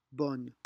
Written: bonne

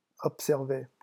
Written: observer

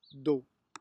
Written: dos

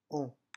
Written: on

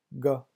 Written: gars

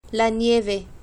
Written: la nieve

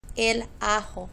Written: el ajo

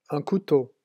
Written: un couteau

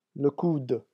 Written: le coude

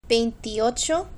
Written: veintiocho

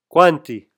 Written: quanti

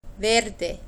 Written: verde

